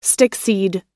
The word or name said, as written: stickseed